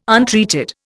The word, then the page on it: untreated: Indian